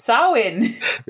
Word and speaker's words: Samhain: In my dream, I found a solid etymological connection between Samhain and the minced oath Sam Hill. – It's pronounced Samhain. – Is it really? – Yeah. The MH is a W.